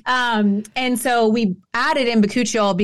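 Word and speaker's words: bakuchiol: I did see that your retinol also has, is it, how do you pronounce it? – Bacuchiol? – Yeah, Bacuchiol.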